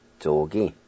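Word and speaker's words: tsogi: Word number one is upstream, and it's pronounced zo gi, zo gi, zo gi.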